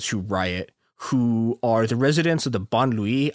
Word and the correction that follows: banlieue: I th- oh, Bon, that's not how you pronounce it. God damn it. Okay. Before before before I did this, I looked up how you pronounce it and I've now forgotten. It's Bonlieu. That that's how you.